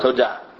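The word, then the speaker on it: Todah (תודה): Todah.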